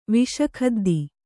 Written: ವಿಷಖದ್ದಿ

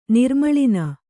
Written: ನಿರ್ಮೂಳನ

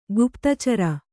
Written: ಗುಪ್ತಚಾರ

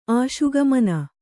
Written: ಆಶುಗಮನ

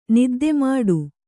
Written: ನಿದ್ದೆಮಾಡು

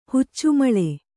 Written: ಹುಚ್ಚುಮಳೆ

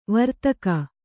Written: ವ್ರತಿಕ

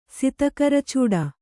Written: ಸಿತಕರಚೂಡ

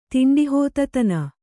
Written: ತಿಂಡಿಹೋತತನ

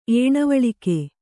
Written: ಏಣವಳಿಕೆ

